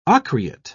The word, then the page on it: ocreate: British English